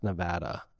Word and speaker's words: Nevada: But then right before the show, they were like, it's pronounced Nevada.